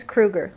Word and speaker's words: Kruger: And then it's Theans Kruger, like Freddy Krueger.